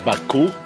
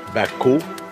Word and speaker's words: Baku: His name is Baku, and you can see Baku. – The name is phonetically engraved. The crane and the basket stand for the sounds bak, and the chick stands for the sound u.